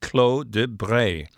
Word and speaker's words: Clos de Bray: So he features this wine, local wine, from a vineyard called Clos de Bray. – Yes. – Did I say that right? – You did.